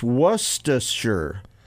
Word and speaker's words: Worcestershire: Worcestershire. It's not Worcestershire. It's Worcestershire. Worcestershire. That's not the first R is silent.